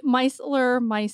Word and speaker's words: Micellar: Micellar. – Yep. – Did I say it right? – Yep. Micellar.